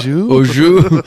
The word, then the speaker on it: au jus: Au jus.